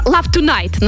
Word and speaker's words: Love Tonight: Да, «Love Toning». Ну, если правильно произношу. – Ну, почти правильно. «Love Tonight» называется она.